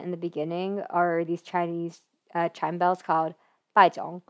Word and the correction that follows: Bianzhong: And Bianzhong. I butchered that the first time.